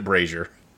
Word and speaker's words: brazier: Okay, I just used the dictionary thing on Google, and yes, it pronounced it brazier.